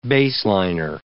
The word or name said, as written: baseliner